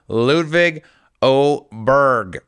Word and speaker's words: Ludvig Åberg: Ludvig Åberg, by the way, is how you pronounce his name.